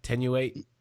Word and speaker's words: Tenuate: Did I say that right, Doc? Is that Tenuate? – Yes, you did.